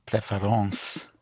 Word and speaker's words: préférence: Both ENCE and ANCE in French are pronounced ans. Like difference would be différence. Yes. Preference would be préférence.